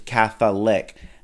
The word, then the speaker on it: Catholic: Catholic. – Kinds of things. Catholic, three syllables.